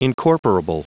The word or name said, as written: incorporable